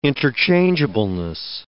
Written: interchangeableness

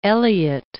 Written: Elliott

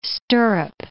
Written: stirrup

